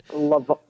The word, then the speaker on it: Levant: Am I saying that right? – Levant. – Levant. Thank you for that.